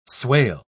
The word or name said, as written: swale